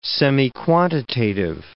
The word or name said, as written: semiquantitative